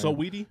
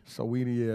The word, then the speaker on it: Saweetie: Sweetie or Swati. I forgot how you pronounce her name. – Saweetie. – Saweetie, yeah.